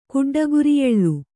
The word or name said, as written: ಕುಡ್ಡಗುರಿಯೆಳ್ಳು